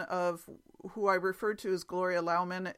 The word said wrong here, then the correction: Loughman: It's Luffman It's spelled L-O-U-G-H-M-A-N, and Maureen tells me that it's Gloria Luffman.